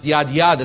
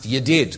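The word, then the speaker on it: yadid (יָדִיד): Now, for pronunciation reasons, it's not pronounced yad, yad, it's pronounced yadid, because that makes it tighter and shorter.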